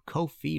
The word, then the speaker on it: Ko-fi: All right, if you like this show, want to support future episodes, please go to Ko-fi, rhymes with no fee, .com/MartinPopoff.